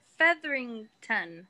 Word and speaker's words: Featherington: But there's like these like budding little stories happening with like everyone in her family and everyone in the Featherton family. Is that what their last name is? – Featherington. – Featherington. Thank you. – Is it? I know there's an extra syllable in there.